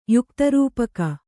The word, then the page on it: ಯುಕ್ತರೂಪಕ: kannada